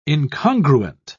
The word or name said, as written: incongruent